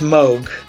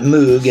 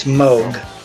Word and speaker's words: Moog: The brand is Moog, which rhymes with Vogue. Yep. And Rogue, but it's not Moog, it's Moog.